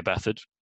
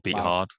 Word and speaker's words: Beathard: CJ Beathard, or Beat Hard, however you want to pronounce it. I prefer Beat Hard.